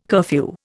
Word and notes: curfew: Indian

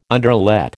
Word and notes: underlet: American English

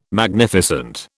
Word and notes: Magnificent: British English

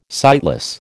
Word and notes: sightless: American English

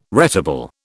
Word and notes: retable: British English

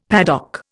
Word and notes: paddock: Indian

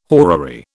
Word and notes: horary: British English

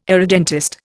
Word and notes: irredentist: Indian

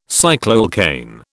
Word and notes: cycloalkane: British English

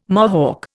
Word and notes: Mohawk: Indian

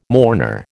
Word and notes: mourner: American English

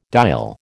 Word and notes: diol: American English

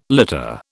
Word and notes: litter: British English

